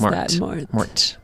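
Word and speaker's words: Morte: You'd actually want to pronounce Mort. Because Mort. – There's an E at the end.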